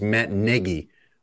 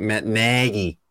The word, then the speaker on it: Nagy: No one other than Lawrence or Michael Kim on TV pronounces that as Matt Negi, which is how he pronounces it, like rhymes with leggy. Everyone else is doing that, like just Chicago accent, Matt Nagy.